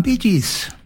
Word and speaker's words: Bee Gees: Og så av det at du skriver det slik som du uttaler det, så ble det Bee Gees.